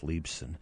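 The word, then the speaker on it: Leibsohn: If you want to see a picture of these, you can go to my Twitter page, at Seth Leibson. And I spell my name differently than it's pronounced. It's L-E-I-B-S-O-H-N.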